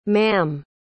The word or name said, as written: maam